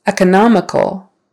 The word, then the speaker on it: economical: Economical, economical. So third syllable stress, economical, economical.